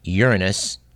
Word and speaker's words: Uranus: but that it is in fact correct to say Uranus, not the alternative pronunciation more commonly used, and that Chiron is apparently a large comet, something like 100 miles across.